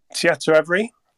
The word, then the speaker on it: Tietoevry: Tietoevry.